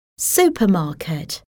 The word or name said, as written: supermarket